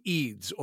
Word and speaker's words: Eid: There's two Eids, or in Arabs will say Eid is the way it's pronounced, right. But Eid, E-I-D, is what it is.